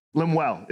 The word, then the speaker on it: Lemuel: Lemuel is actually how you pronounce it.